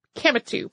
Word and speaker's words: Kemetu: They called their language and culture Kemptu, by the way. I think that's Kemetu, by the way, which you'll find spelled in several different ways. So maybe one of those is the way I pronounced it the first time.